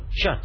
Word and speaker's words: kyat: The currency is called Chat, but it's spelled K-Y-A-T.